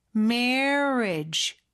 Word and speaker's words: marriage: 그리고 결혼이라는 marriage 요거는 marriage 이렇게 돼 있지만 may하고 re 사이에 어가 살짝 숨어 있다고 생각하면서 입모양을 그렇게 만들어 주세요. marriage, marriage 그렇게 발음해 주시면 조금 더 원어민처럼 하실 수 있을 거예요.